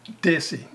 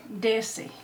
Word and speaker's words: Desi: How do you say that? – Desi. – Desi.